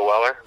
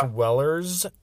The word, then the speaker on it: Weller: I think it's pronounced Wellers, according to Derek. – Wellers. Yeah, see, I'm not even on that level. – Derek chastised me for putting an S at the end of it the other day.